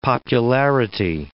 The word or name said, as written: popularity